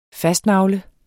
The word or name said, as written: fastnagle